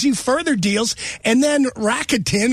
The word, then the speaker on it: Rakuten: I saw it advertised on TV and I didn't know how to pronounce it. And then their advertisements were actually making fun of the pronunciation. So people are like, funny. – People are like, well, Rockatoon, whatever.